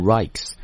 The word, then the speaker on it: Rijks: 那么发音的时候呢，这荷兰语这个 J 不发啊，读 Rijks 这样的一个博物馆里面。